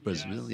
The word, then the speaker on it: Brazile: Is that how it's pronounced? Brazile? Brazile, yeah.